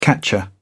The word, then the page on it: catcher: British English